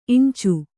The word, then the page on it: ಇಂಚೆ: iñce